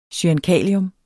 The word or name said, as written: cyankalium